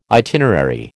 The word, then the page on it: itinerary: American English